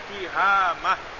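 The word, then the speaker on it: tihamah (تهامة): Tihamah bikasrita ilmu sannah, bacanya tihamah.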